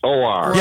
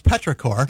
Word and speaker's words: petrichor: Pritchard. – Yes, I was thinking the same thing. How do you pronounce that? – Yeah, so it's actually pronounced Petrichor.